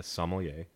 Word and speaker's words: sommelier: Yeah, so I became a sommelier, is how you say it. – A little different accent. – Like, yeah, I feel like I enunciated the wrong, like, put the emphasis on the sommelier. Wrong syllable.